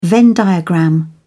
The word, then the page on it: venn diagram: British English